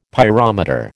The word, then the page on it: pyrometer: American English